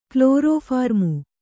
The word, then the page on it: ಕ್ಲೋರೋಫಾರ್ಮು: klorōphārmu; Kannada